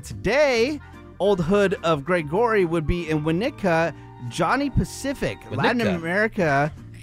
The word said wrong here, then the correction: Winnetka: Did I not say it right? – Winnetka.